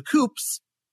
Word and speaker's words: Coops: The United Workers Cooperatives, better known as the Coops, rhymes with stoops,